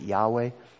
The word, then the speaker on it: Yahweh: I think, and I usually pronounce it Yahweh. When we're talking about the day of the Lord, we're talking about the day of Yahweh here.